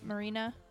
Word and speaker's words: Marina: Is that how you say it? Marina? – Yep.